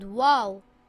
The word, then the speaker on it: Waw: This letter is called Waw.